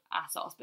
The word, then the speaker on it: ASOS: Is it ASOS, right? – I'm ASOS. But some people say ASOS.